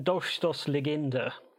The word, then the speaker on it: Dolchstoßlegende: German Dolchstoßlegende. – Good work. – That little, the one that looks like a B is actually a double S.